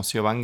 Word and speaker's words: Siobhan: Ah, mi nombre se escribe S-I-O-B alta H-A-N. Y si me buscan en YouTube como Siobhan Guerrero McManus, y el apellido también hay que deletrearlo, es como McDonald's, MC.